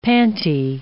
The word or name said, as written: pantie